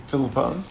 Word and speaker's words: Filippone: Filippone.